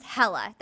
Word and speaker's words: Hala: my name is Hella, that's how you say my name. But it's spelled H-A-L-A, so I always told everybody it was Hala,